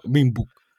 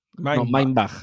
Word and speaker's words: Mindbug: O si lo decimos a lo cateto, Minbug. – Exacto. – Sí, eh, supongo que eh, se escribe Minbug, pero supongo que se dirá Mindbug. – Mindbug. No, Mindbug.